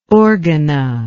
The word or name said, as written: Organa